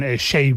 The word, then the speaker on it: Seyi: Pasa, por ejemplo, con Shay Vibes, ¿no?, que se escribe Seyi, S-E-Y-I.